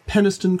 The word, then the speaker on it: Penistone: For the listeners, it is Penistone Crags, but it's spelled...